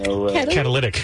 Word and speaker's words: catalytic: It's a Cadillac? – Catalytic. – It's not a Cadillac? Like a car Cadillac? – Catalytic converters.